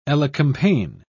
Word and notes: elecampane: British English

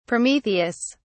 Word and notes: prometheus: British English

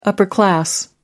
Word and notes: upper-class: American English

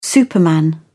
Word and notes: superman: British English

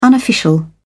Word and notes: unofficial: British English

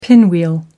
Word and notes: pinwheel: American English

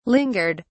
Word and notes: lingered: British English

